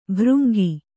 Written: ಭೃಂಗಿ

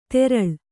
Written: ದರಳೆ

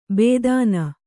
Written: ಭೇದನ

